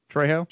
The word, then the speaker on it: Trejo: Oh, and can we forget Machete himself, Danny, is it Trejo? How do you say his last name? Trejo.